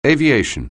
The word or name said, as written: aviation